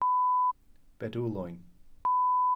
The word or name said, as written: bedwlwyn